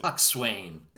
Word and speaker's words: coxswain: Coxswain. – It is pronounced coxswain. You are correct that it is spelled coxswain, but no, I didn't know that.